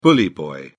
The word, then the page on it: bully boy: British English